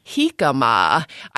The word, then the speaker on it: jicama: You might pronounce it jicama. Isn't that how people mispronounce it all the time? – Yeah, well, it's spelled J-I-C-A-M-A. It's pronounced jicama or jicama. I think actually technically the correct pronunciation is jicama.